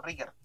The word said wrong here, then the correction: Richter: no sé cómo se pronuncia en verdad el nombre culiado. Eh, ah, no, es Richter.